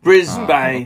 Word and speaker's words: Brisbane: Is that not pronounced Brisbane? – I like to mispronounce everything. Brisbane, okay.